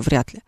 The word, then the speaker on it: вряд ли: Ну, то есть, например, слово «вряд ли» по-хорошему надо произносить. Не «вряд ли», хотя я всегда говорю «вряд ли», а, а «вряд ли».